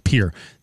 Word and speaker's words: Pierre: You were pronouncing the capital of South Dakota wrong. It is pronounced Pierre.